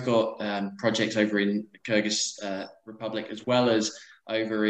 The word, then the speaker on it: Kyrgyz: The Kyrgyz state. The Kyrgyz Republic.